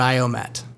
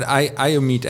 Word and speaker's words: IOMETE: Tell me exactly about IOMETE.